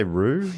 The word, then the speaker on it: rue: Is that at least how you say rue? – Yeah, yeah.